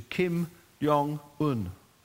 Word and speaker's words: Kim Jong-un: All at once, no longer is that North Korean, former North Korean citizen, subject to Kim Jong-un. That's how you say his name.